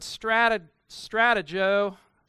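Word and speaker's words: strategō (στρατηγός): So strategy defined, the Greek word strategō, that's how it's pronounced, means the general's view of the battlefield.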